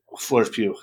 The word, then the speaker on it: Florence Pugh: That's how you say her name, right? Florence Pugh. There you go.